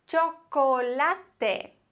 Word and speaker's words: chocolate: CH wird wie ein deutsches TSCH ausgesprochen. Tsch. Tsch. Beispiele dafür wären El Chico, der Junge, Chico. Oder Chino, Chinesisch, Chino. Okay? Chico, Chino. Da brauchen wir TSCH, müssen wir uns zumindest so vorstellen. Oder auch bei dem Wort Chocolate. Chocolate. Da haben wir am Anfang Ch. Choco.